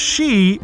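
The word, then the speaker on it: sídhe: The plural of she is she, pronounced the same, but spelled S-I, often with an acute accent, D-H-E.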